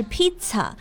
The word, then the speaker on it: pizza: 嗯，p pizza，对，叫 pizza，我们不是披萨，而是 pizza。